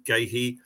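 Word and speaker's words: Guéhi: So, Goitre in goal, Ward, Gahey. Correct pronunciation, Gahey.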